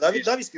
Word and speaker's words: David Davis: David Davis doğru mu okudum? David Davis diye mi okudum? David, David Davis. diye okunuyor. O kendisi öyle okuyor.